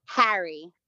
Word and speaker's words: Harry: Do I say your last name Hari or Harry? I want to make sure I get this right. – It's Harry. – Harry, like Harry Potter.